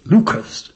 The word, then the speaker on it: Leuchars: a place which you have to give a slight cough to pronounce, Lucas. Between Dundee and St Andrews. – Just spell it for you. – L-E-U-C-H-A-R-S.